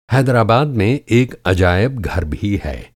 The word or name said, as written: हैदराबाद